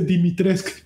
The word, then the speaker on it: Dimitrescu: No se dice Lady Dimitrescu se dice Lady Dimitrescu. Digo, no, no, no, no, no, no mames, mija, y no me vengas a decir mamadas. Dimitrescu. Eh, no se dice Dimitrescu.